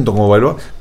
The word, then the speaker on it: Tagovailoa: Fíjate que en el caso de Tua Tagovailoa, que luego nos dicen, si es que se escribe Tagovailoa, ¿por qué dicen Tagovailoa? Pues así se pronuncia.